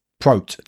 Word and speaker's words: prot: Prote. – Yeah, to rhyme with scrote.